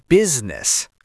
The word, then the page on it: business: American English